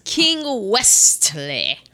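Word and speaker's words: Westley: Wesley. There's a T in it. – Is there? – Yes, it's King Wesley.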